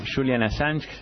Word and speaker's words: Julian Assange: Julian Assange.